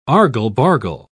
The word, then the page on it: argle-bargle: British English